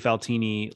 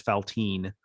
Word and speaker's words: Faltini: I definitely mispronounced Trey Faltini like at least halfway into his first season as Faltine.